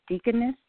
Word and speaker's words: Deaconess: Is it Deaconess, Dr. Frank? – That's correct, yes.